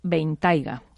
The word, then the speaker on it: Bentayga: entre ellos el moderno todoterreno Bentley Bentayga. ¿Lo he dicho bien ahora? – Una maravilla. Fenomenal.